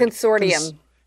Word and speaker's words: consortium: How do you say that word? – Consortium. – Consortium. Thank you. – Today's word is consortium. – Okay, the T sounds like a D.